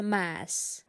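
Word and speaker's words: Mads: Как мы с тобой выяснили, он правильно произносится «масс».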